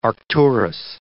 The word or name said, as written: Arcturus